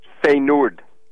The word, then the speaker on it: Feyenoord: How do you say it? – It's Feyenoord. – Yeah, them. Those guys. – Feyenoord.